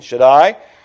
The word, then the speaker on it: Shaddai: And it says, under the shadow of the Almighty Shaddai, S-H-A-D-D-A-H-E-E, if you want a pronunciation, Shaddai.